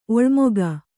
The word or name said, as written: ಒಲ್ಮೆಗ